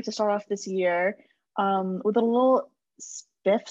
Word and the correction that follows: spliff: slips because we record... – Spliff slips. – What did I just say? – Spiff. – I said it really slowly because I knew I was going to say it wrong and I still said it wrong.